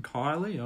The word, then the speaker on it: Kiely: Megan Kiely, I presume you pronounce it, K-I-E-L-Y is her surname, kicked two goals.